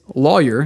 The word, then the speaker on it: lawyer: In most of the United States, lawyer is pronounced to rhyme with boy. – Like boyer. Boyer, lawyer. – In the South, excluding Texas and Florida, not really South, I've been told, it's pronounced to rhyme with Saul.